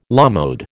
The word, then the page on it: à la mode: American English